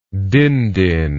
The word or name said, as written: dindin